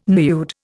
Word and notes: nude: Indian

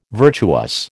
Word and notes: virtuous: American English

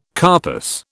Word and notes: carpus: British English

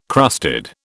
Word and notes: crusted: British English

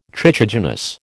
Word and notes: trichogenous: American English

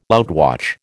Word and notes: outwatch: American English

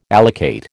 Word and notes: allocate: American English

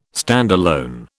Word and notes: standalone: British English

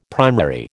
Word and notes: primary: American English

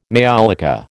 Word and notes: maiolica: American English

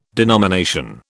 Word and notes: denomination: British English

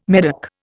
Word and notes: Medoc: Indian

